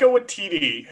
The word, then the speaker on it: Waititi: Taika Waititi, who is a director who is most famous for, Thor Ragnarok at this point.